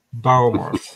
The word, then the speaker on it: Bournemouth: Bournemouth, yes.